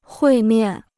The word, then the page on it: 会面: huì miàn